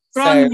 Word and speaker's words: Gráinne: Today I'm speaking to another one of my Elizabeths, Gráinne. Tell me if I pronounced that wrong. – it's Gráinne. – Gráinne.